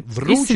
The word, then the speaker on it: вручит: Два слова могут поменять ударение. Например, такие варианты, как «вручит» и сверлит могут произноситься вручит теперь вручит и сверлит.